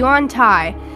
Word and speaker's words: Yontai: They're nowhere near each other, but there is one, how do you say it? Like a Yonti? The snake thing? Oh, okay, a Yontai.